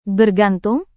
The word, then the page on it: bergantung: Indonesian